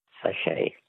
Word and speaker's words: sachet: Sachet. – A sachet. S-A-C-H-E-T. Well, they were too easy for you, so we're not asking you any more words that end with a silent T, mainly because there are no more questions in that category.